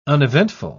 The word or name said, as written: uneventful